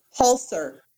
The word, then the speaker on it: Holzer: And this is on the Holzer Files. What is it? The Holzer Files? – Holzer. Hans Holzer. – Holst. – He's originally Austrian. – Holzer. – H-O-L-Z-E-R.